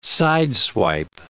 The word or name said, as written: sideswipe